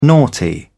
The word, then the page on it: naughty: British English